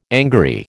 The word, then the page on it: angary: American English